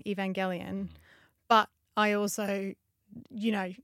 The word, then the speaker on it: Evangelion: But yeah, it is definitely hard G, and the Gelion of Evangelion is definitely a hard G.